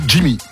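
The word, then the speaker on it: XGIMI: Oui, c'est Jimmy. – C'est X Jimmy? – Ouais, mais je crois qu'on dit que Jimmy, en fait.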